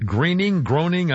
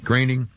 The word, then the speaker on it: Groening: The Simpsons airs its 400th episode, created by Matt Groening, I never pronounce his name right. – Groening.